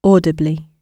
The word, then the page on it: audibly: British English